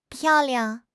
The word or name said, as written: piao4 liang5